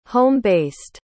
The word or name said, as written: home-based